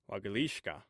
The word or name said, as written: wagthishka